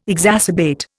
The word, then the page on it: exacerbate: Indian